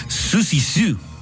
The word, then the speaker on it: Siouxsie Sioux: Hoy cumple 69 años la artista británica Siouxsie Sioux, se pronuncia, perdóneme usted, Siouxsie Sioux, ahí está más fácil.